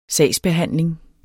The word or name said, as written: sagsbehandling